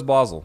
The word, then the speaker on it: Basel: Is that pronounced Basel? I would have guessed Basel. Basel? – Yeah, I thought so too, but he says Basel, so I'll follow his lead.